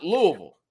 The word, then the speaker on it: Louisville: Because some people say Louisville. Louisville or Louisville. No. It's Louisville.